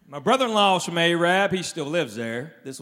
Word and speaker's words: Arab: If you tell about Arab, it's spelled A-R-A-B. They don't pronounce it Arab. They pronounce it Arab, because the original name was Arad, but they misspelled it on the water tower.